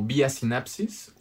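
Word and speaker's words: Vía Synapsis: Pero eh, si, nos, si nos buscan como Vía Sinapsis, V-I-A-S-Y-N-A-P-S-I-S, s i s Órale.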